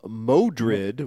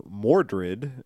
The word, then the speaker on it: Modred: He's running into they call him Modred, which is one of the different pronunciations of Mordred, and who is the main bad guy in the Arthur story.